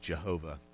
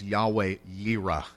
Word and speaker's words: Yahweh Yireh: And it's not actually pronounced Jehovah. That is an English butchering. I just have to throw that out, not that it matters. It's Yahweh Yireh is what you would really say if we spoke Hebrew.